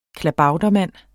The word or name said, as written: klabautermand